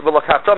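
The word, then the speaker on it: Ve'lokachta (וְלָקַחְתָּ): Ve'lokachta means you should take. Ve'lokachta, you stress the last syllable, means you should take.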